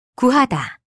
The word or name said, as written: 구하다